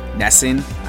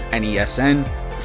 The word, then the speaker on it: NESN: Alex is a writer for NESN. I found out from her that's actually how you say it. I always thought it was NESN, so I'm thankful that she was able to culture me there a little bit.